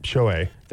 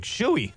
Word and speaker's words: Shohei: But There's a lot of people that leave out that second H. It's not, they don't call him Shohei. – Shohei. – They're like Shoei.